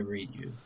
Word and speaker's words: Ryu: So for y'all who don't know, Fortnite just, you know, did a trailer pretty much introducing Chun-Li and Ryu, or however you want to say it. I say Ryu.